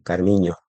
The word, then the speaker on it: Carminho: Carminho, que la ñ en portugués se escribe NH, Carmiño.